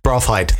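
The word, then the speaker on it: Braafheid: Edson Braffield or Braffied. – Braffhide? – Braffhide, that'll be more like it.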